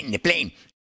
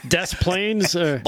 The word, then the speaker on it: Des Plaines: How do you say that? – DePlain. – Des Plaines.